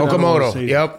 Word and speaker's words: Okamoto: Okamoto, am I saying it right? – Okamoto, yep, that's right.